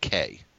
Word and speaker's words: quay: I was able to, he opened up a triple word square, and I played Quay. – Yeah. – And this got I think we pronounce it K.